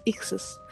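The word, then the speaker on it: Ixus (ἰχθύς): Entonces, esto viene de el griego, de la, de la palabra en griego ictus, ictus. ¿verdad? Es la pronunciación más o menos, Ixus.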